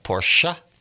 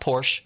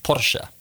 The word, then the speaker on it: Porsche: Porsche. It's 2 syllables, not Porsche. You know, it's not Porsche. It's Porsche. Porsche.